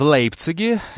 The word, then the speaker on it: Лейпциг: В Лейпциге. Я решил, что Лейпциг произносится именно так, с этой минуты.